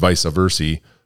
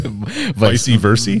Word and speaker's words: vice versa: and vice versa for a corn volunteer corn. Didn't I say that right? Vice versa? – No. Vice versa.